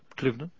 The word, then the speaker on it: Cliveden: Cliveden.